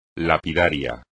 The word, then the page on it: lapidario: espagnol